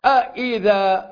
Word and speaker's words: أإذا: ثلاثة أشياء في أئذة، همزتان متتابعتان تنطق الأولى من الأعلى أ، وتنطق الثانية من الأسفل إي، كونت نبرة صوتية جميلة سريعة أئي، اختصرت السؤال ومعنى الشرط والظرفية في صوتين فقط أإذا، وجوابها يكون في سطور.